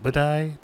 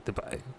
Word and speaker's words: Dubai: All I know is Dubai. Wait, Budai? Dubai.